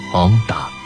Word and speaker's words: Honda: 因为意大利语 H 不发音，所以 Honda 就变成了 Honda。